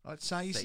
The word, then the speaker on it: Saiss: Saiss is normal. That's how you pronounce it.